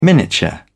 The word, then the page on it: miniature: British English